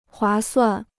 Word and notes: 划算: huá suàn

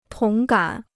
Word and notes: 同感: tóng gǎn

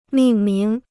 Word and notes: 命名: mìng míng

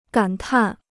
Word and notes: 感叹: gǎn tàn

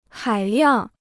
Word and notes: 海量: hǎi liàng

